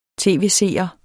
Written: tv-seer

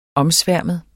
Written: omsværmet

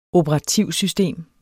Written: OPERATIVSYSTEMER

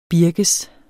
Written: birkes

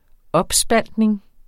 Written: opspaltning

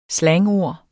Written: slangord

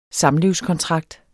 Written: samlivskontrakt

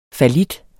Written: fallit

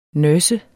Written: nurse

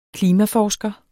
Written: klimaforsker

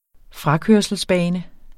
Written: frakørselsbane

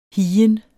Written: higen

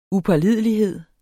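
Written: upålidelighed

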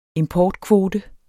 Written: importkvote

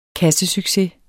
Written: KASSESUCCES

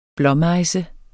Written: blåmejse

